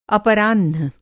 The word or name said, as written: अपराह्ण